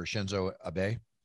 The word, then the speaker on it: Shinzo Abe: Former Japanese Prime Minister Shinzo Abe, if I'm saying his name correctly, yep.